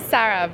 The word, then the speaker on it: Sara: My name's Sarah, but it's spelled like S-A-R-A, and everyone says Sarah. Even though there's no H, everyone reads it wrong.